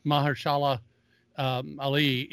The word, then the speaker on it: Mahershala Ali: Well, Cottonmouth, Ali, Mahershala Ali. – Said it right.